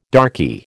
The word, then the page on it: darky: American English